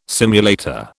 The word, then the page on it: simulator: British English